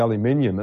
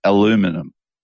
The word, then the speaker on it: aluminum: when you remove the aluminium, it's got a coating of plastic inside it. So you're getting all the bias. – You're not even saying it correctly. It's aluminum. – Aluminum. – In America, aluminum.